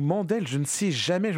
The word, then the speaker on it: Mendel: Mandel ou Mendel. La prononciation exacte, je crois que nous, on disait Mendel.